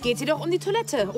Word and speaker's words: Toilette: Im Französischen wird das E am Ende nicht ausgesprochen, im Deutschen hingegen schon. Toilette.